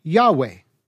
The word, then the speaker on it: Yahweh: In fact, the largely accepted scholarly pronunciation of the name is Yahweh, which is apparently reconstructed partly from Hebrew, partly from other Semitic languages, and partly from Greek transliterations found in early Christian writings.